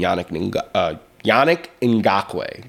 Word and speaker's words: Yannick Ngakoue: Yannick Ngakoue. Did I say that correctly? – Yeah, you did.